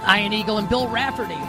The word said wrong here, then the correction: Raftery: with the call this weekend. Raftery? I pronounced it incorrectly.